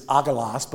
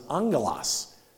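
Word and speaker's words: angelos (ἄγγελος): So in the Bible, in Greek, it's spelled A-L-L. But L-L in Greek is actually pronounced, or I should say, is that A-G-G? A-G-G in Greek, gamma gamma, is N-G. That's how we end up with angels. Because it's agalos, but you don't pronounce it that way in the Greek. You pronounce it angelos.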